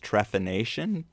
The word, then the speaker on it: trephination: You know, when we first meet him, he's fascinated by this device called I guess it's pronounced trephination brace. – Yes.